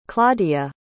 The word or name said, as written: claudia